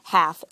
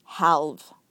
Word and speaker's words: half: Half, H-A-L-F. And when you said it, you pronounced the L, so you said half. And the L is silent, so it should be half.